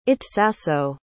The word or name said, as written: itsaso